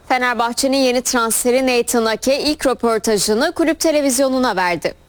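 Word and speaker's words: Nathan Aké: Nasıl telaffuz edildiğinin bir önemi yok ama Hollanda'da Nathan Ake diye telaffuz ediliyor. Bu şekilde söyleyebilirsiniz.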